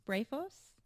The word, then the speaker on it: brephos: And in Luke, you know, John the Baptist leaps in Elizabeth's womb, and Luke refers to him as Beatros, or can you pronounce it for me? Brephos.